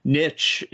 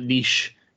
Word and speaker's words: niche: Like, for example, niche in the U.S., we pronounce it niche here. – Niche, yeah.